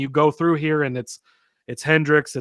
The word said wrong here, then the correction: Hendrick: It's 2311. Hendrick. Sorry, it's not Hendrix. Hendrick.